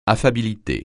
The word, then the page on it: affabilité: French